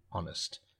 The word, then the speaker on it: honest: Here, though, honest is, the H is silent, and we're pronouncing O-N-E-S-T, honest.